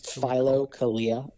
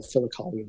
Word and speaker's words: Philokalia: It's Philokalia is how it's spelled. So Philokalia is how it's pronounced.